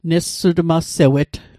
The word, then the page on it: nessutmalsewet: Mi'gmaq